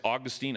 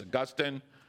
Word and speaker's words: Augustine: And and Augustine, or if you mispronounce his name as Augustine, I will forgive you, but it's Augustine. Augustine is a city in Florida.